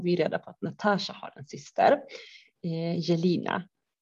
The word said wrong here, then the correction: Jelena: Uttalar jag rätt Bian? Jelina. – Jelena. Ja, Jelena till och med.